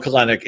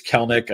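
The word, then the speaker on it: Kelenic: And also, I'm glad we're pronouncing it right. It's not – everybody wants to call him Kelnick. It's Kelnick. I know it doesn't make sense the way it's spelled, but you want pieces of the Atlanta offense, and he's the cheapest way in.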